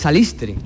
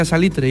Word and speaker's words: salitre: La verdad que lo vimos claro desde el primer momento, porque nosotros en Barbate se dice salistre. – Claro, claro. – Está mal dicho.